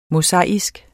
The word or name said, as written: mosaisk